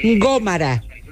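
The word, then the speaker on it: Gómara: Con el problema de los acentos, hay una avenida en Sevilla, yo conozco la Casa Condal de Soria, que se llama, aquí le dicen, Avenida López de Gomara, y es Avenida de López con acento, Gomara, con acento en la O de Go.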